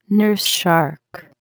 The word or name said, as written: nurse shark